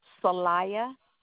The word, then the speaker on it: Celaya: Rebecca Salaya with a C. – Could you spell that, please? – C-E-L-A-Y-A.